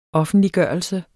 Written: offentliggørelse

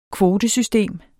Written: kvotesystem

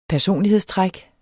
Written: personlighedstræk

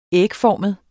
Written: ægformet

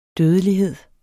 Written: dødelighed

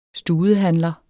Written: studehandler

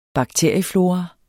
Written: bakterieflora

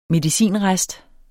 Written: medicinrest

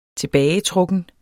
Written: TILBAGETRUKKEN

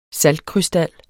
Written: SALTKRYSTAL

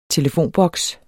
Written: telefonboks

